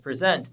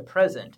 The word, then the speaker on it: present: The word present as a verb is pronounced present, but when it's a noun, it's a present.